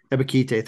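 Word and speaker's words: Ebiketie: I always pronounce his name wrong. You just said it great. Ebikite or whatever it is. – Yeah. – Ebikite, thank you.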